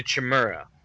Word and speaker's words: Chimera: You mean the Chimera? – Sorry, the Chimera. – Ooh, you said it correctly. Chimera.